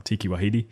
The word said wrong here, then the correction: Taika Waititi: Taika Waititi. – I don't know. – Well, we just said it. It's Taika Waititi.